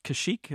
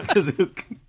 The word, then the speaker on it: Kashyyyk: the planet of the Wookiees, Kashyyyk, is that right? – Yeah, although they can't pronounce it in the show, that's for sure. – Kazook. Canonically, Kazook.